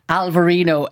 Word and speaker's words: Alvarinho: And the pronunciation and the spelling is slightly different. Alvarino, A-L-V-A-R-I-N-H-O. But it's basically the same grape variety.